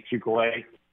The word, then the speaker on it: Chuculate: Yes, Eddie Chuculate. It's spelled like chocolate, only the O's are used in my name. Chocolate is C-H-O-C-O-L-A-T-E, and Chuculate is C-H-U-C-U-L-A-T-E.